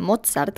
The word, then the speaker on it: Mozart: El señor Mozart, o como dice Juan, Mozart, que es como se pronunciaría de manera adecuada, se llamaba Johannes Chrysostomus Wolfgangus Theophilus Amadeus Mozart.